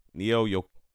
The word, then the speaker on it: Neo Yokio: Neo Yokito. Oh, Neo Yokito. Yeah, Neo Yokio.